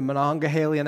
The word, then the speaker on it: Monongahela: But if you were on a hiking trip this weekend in the Monongahela National Forest, and did I say that wrong? – No.